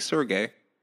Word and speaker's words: Serge: The proper way to pronounce my name is Serge.